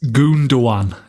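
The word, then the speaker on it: Gündoğan: Is Gundogan correct? – It's Gundogan is how you're supposed to say it, I think.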